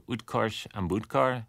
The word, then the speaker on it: Utkarsh Ambudkar: And you talked to the actor Utkarsh Ambudkar, am I saying his name right? – That's right.